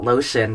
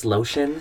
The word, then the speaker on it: LO•TI•ON: It's about lotion, but pronounced. I thought it was lotion.